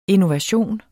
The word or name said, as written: INNOVATIONEN